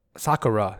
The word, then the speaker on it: Sakura: Sakura is actually how you pronounce it, I believe.